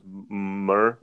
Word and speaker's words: myrrh: And and I don't know how to pronounce this. Mar. – It was myrrh, myrrh, myrrh, Yeah, yeah.